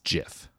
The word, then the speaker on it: GIF: One, it should be a hard G, because the G stands for graphic. – Uh-huh. – So the only reason why he's saying that it's GIF